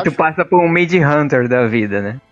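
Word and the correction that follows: Mindhunter: Mind Hunter, na verdade, é. eu falei errado.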